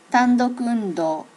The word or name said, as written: tandoku undo